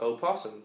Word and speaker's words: opossum: Do you pronounce the O in opossum? It's usually pronounced opossum when spelled with the initial O. Many people shorten it for convenience to possum.